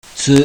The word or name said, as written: TU